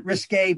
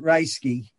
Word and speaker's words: Ryesky: First of all, the French branch of my family pronounces it Risque, but I pronounce it Ryesky.